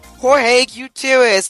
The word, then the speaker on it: Jorge Gutierrez: Right now, we're talking with writer and director of The Book of Life, Jorge Gutierrez.